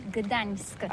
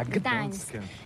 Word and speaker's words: Gdańsk: Oui, c'est pas très facile pour des étrangers, mais remarque bien que la langue polonaise, c'est la langue où on prononce chaque lettre qu'on écrit. C'est pourquoi on dit Gdańsk. – Ah, Gdańsk.